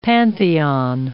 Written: Pantheon

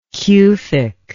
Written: cufic

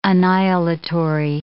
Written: annihilatory

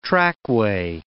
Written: trackway

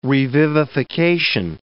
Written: revivification

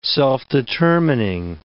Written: self-determining

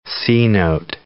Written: C-note

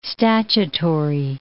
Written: statutory

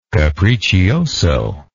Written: capriccioso

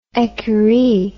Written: ecurie

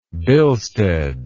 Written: bilsted

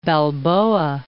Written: Balboa Heights

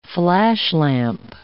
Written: flashlamp

